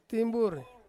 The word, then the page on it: timburi: Ansus